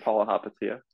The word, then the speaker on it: Palihapitiya: Palihapitiya.